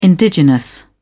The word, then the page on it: indigenous: British English